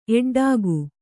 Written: ಎಡ್ಡಾಗು

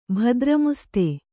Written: ಭದ್ರಮುಸ್ತೆ

